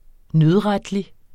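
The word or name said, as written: nødretlig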